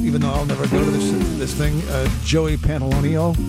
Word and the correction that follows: Pantoliano: Am I saying his name right? I'm not saying his name properly, am I? Joey Pants. You know this guy? Joe Pantoliano, thank you, that's it.